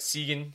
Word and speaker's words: Seguin: That's how it's pronounced? – It is. It's Seguin. He's third in the NHL in scoring.